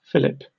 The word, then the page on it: fillip: British English